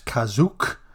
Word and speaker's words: Kashyyyk: In the special, Kashyyyk is pronounced as Kazook and is spelled that way in the script, reflecting Lucas's early notes on the Wookiee homeworld.